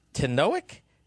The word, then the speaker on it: Tanoak: The Tanoak.